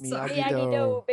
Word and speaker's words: Miyagi-Do: Miyagi-Do, baby. – Miyagi-Do.